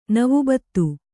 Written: ನವಬತ್ತು

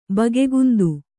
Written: ಬಗೆಗುಂದು

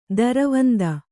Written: ದೂರವಂದೆ

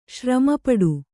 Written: ಶ್ರಮಪಡು

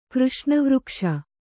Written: ಕೃಷ್ಣವೃಕ್ಷ

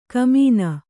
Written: ಘಮ್ಮನ

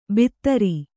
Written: ಭಿತ್ತರಿ

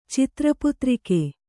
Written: ಚಿತ್ರಪುತ್ರಿಕೆ

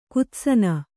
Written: ಕುತ್ಸನ

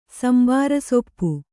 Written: ಸಂಬಾರಸೊಪ್ಪು

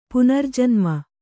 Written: ಪುನರ್ಜನ್ಮ

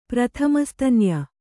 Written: ಪ್ರಥಮಸ್ತನ್ಯ

